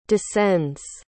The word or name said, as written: descends